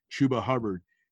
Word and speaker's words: Chuba Hubbard: Chuba Hubbard and Mike Gundy. – I was wondering how you were going to say his name. One B and then two Bs.